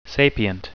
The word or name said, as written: sapient